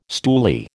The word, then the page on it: stoolie: American English